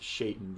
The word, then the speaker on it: Shaitan: I'm convinced it's pronounced Shaitan because it looks like a small little Satan, just a Yeah, I know.